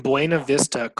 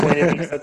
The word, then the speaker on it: Buena Vista: Everything you pronounce in Spanish. Buena Ahoy. Vista. Buena, Buena Vista. It's Buena Vista. Sorry, guys. It's Buena Vista, Colorado, not Buena Vista.